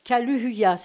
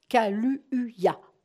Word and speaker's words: Kaluuya: Kaluuya, c'est pas facile à prononcer parce qu'il y a deux U qui se suivent. Kaluuya, acteur britannique, voilà.